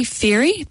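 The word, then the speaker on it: Fairey: Just search for Julie Ferry, that's spelled F for Fred, A-I-R-E-Y.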